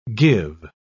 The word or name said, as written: give